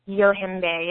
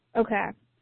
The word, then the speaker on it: Yohimbe: And the last one you mentioned is Yohimbe, is that how I pronounce it? – Yohimbe, yes, Yohimbe.